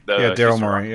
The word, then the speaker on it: Daryl Morey: I like seeing Daryl Morey just because, or however you say his name. – Daryl Morey, yeah.